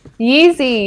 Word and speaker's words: Yeezy: It's Yeezy. Yeezy? Is that how you say it? – Yeezy, yeah.